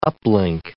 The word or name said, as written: uplink